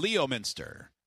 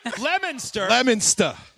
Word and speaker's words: Leominster: Leominster. – Oh, yeah, it's definitely spelled like that. L-E-O, Minster. I see it. – Leominster. – Stop pronouncing the R so hard.